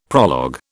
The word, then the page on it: prologue: British English